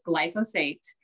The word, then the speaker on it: glyphosate: So for the longest time, I wasn't even pronouncing it right. So it's glyphosate.